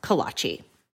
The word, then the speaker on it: Kolache: Both join me to talk about Verdigris Kolach Days, which, by the way, as you will see, I pronounce kolachi.